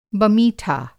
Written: बमीठा

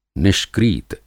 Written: निष्क्रीत

